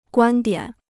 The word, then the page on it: 观点: guān diǎn